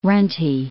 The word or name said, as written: runty